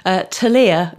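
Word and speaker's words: Ptelea: One of them is called Telea, which is spelt with a P at the beginning. So it's P-T-E-L-E-A.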